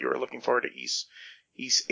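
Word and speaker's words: Ys: And by the way, when we say East, it's Y, capital Y, lowercase s. Some people would pronounce it Yiz, Y's, you know, but apparently it's supposed to be pronounced East.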